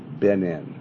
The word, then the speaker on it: Benin: That sounds like Benin, not Benin. Benin, isn't that the way you'd pronounce it? – Benin.